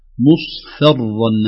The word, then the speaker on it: مصفرا: مصفرا، ننتبه إلى هذه الكلمة، فقد قال لنا أحد مشايخنا سابقا انتبهوا للفاء عند الاصفرار، سواء مصفرا أو مصفرة أو صفراء، الفاء حرف رقيق، حرف مرقق